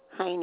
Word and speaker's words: Heine: Catherine Heine, H-E-I-N-E.